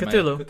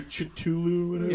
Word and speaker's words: Cthulhu: You've got Cthulhu, or How do you guys pronounce it? – Cthulhu. – Cthulhu. Cthulhu. Okay, okay, good. – You want to do Chitulu? – Yeah, I had one of my friends trying to tell me the other day, it's Cthulhu. Yeah. – No, it's Cthulhu. he said it right.